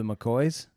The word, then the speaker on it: McCaughey: Wait, so they had, who, the McCoys? – Yeah, it's spelled McCaughey. It looks like it's McCaughey, but it's pronounced McCoy.